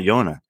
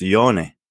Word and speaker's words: Joona: It's not Joona. It's Joone. – Joone.